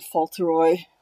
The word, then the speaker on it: Fauntleroy: And they're celebrating his birthday, you know, and he's dressed like little Lord Fauntleroy.